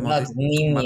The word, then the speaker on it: Madonnina: Derby della Madonnina. No, Derby della Madonnina. Madonnina. – Madonnina. Sorry, I keep... Because Nina means kid in Spanish.